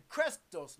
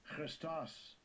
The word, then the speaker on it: Christos: And Christos, let's hear the pronunciation. – Strong's G5547. Christos. Christos.